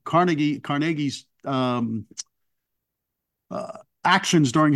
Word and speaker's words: Carnegie: Um, Andrew Carnegie, I should say, to pronounce him as his Scottish way, not not in his American.